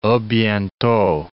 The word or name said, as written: a bientot